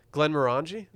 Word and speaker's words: Glenmorangie: Glenmorangie? – That's right.